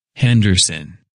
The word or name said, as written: Henderson